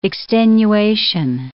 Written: extenuation

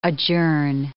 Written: adjourn

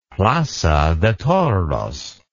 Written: plaza de toros

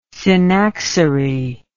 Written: synaxary

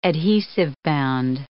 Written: adhesive-bound

